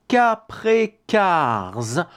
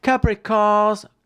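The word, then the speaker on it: Kaprekar's: Ça s'appelle, prenez un papier, un crayon, Capré Cars. Constante, je prononce exprès à la française. Capré Cars. Constant, Capré Cars, K-A-P-R-E, K-A-R, apostrophe S, et constant comme constant, constant, C-O-N, S-T-A-N-T.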